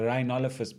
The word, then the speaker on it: Rhinolophus: Now, these bats are known as the Rhinolophus bat. Yeah, I almost got that wrong there. It's a Rhinolophus bat because they look kind of like a rhino, which is weird to be one animal named after the other.